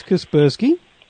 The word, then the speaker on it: Kaspersky: Now, I've got I can't really pronounce it properly, Kaspersky? – Yes, Kaspersky. – Yes, protection on it.